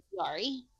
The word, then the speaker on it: Chiari: When you say, I think you're saying Q-R-E? – Chiari. – Chiari. – So it's spelled like Chiari. – Yeah. – Oh, okay. – C-I-A-R-I. – Okay. – But it's Chiari.